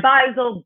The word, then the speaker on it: Beisel: And Amanda Beard's last name is spelled B-E-A-R-D. I'm Beisel, B-E-I-S-E-L.